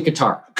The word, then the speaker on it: Qatar: Is it Qatar or Qatar? Or what do we say? What do we call it? – I say Qatar. – Qatar.